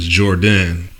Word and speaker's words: Jordn: Well, the name, I got it from, well, of course, my name is Jordan, but the way I spell it is J-O-R-D-N, the rap way.